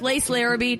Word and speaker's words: Lace Larrabee: You guys, I'm here with Lace Larrabee. Do I say that right? – You said it perfect.